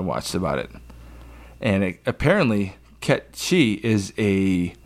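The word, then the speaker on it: Cait Sith: Kate Sith. – All right, that's what I called that character for 20 years. – Kate Sith. – It's Ket She.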